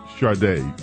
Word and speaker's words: Sade: A little jazzy Sade taking us in.